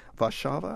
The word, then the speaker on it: Warszawa: it Warszawa. I think that's what the polls say.